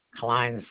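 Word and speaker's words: Kline: Klein Sackman.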